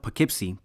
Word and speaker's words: Poughkeepsie: La primera colonización cerca de Fallkill retuvo su nombre indígena de Wappinger, Upuki Ipising, pronunciado Poughkeepsie por los europeos, con el significado de cabaña cubierta en juncos cerca del pequeño lugar de agua.